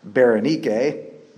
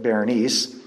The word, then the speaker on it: Berenice: And while Paul waited for the transfer to Rome, King Agrippa and Berenice, probably better pronounced Berenice, but I'll stick with Berenice, came to visit Festus, probably for the reason to congratulate him on his new commission.